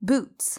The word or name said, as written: boots